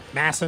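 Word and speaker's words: MASN: I think they call it Mason or whatever. – Yeah, Masson. Mass, Masson. That's what it is. Mass, duh, Massachusetts. Masson.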